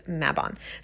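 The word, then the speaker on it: Mabon: But it is Welsh. The background is Welsh. And so I do like, you know, to pronounce it as close to that as possible, which I believe is Maybon.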